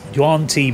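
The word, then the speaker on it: Yuan-ti: So yes, this is a Huan Ti Malison. How you pronounce it? Yeah, Huan Ti.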